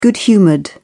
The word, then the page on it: good-humoured: British English